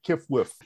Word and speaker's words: Qfwfq: but I realized I'm pretty sure that the narrator of Cosmic Comics is QFWFQ, not QWFWQ. He said QWFWQ, I think. – Did I? I mean, I remember pronouncing it Kifwif, and it's possible that my brain glitched and I figured it was just an honest mistake.